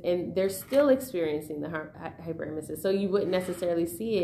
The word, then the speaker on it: hyperemesis: and all of a sudden now you're experiencing this hyperemesis. And that's how you pronounce it, by the way.